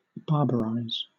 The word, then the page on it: barbarize: British English